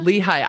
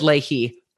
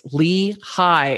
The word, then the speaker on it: Lehigh: But I think I said Leahy at least a couple of times. They're like, it's Lee High.